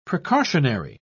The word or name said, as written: precautionary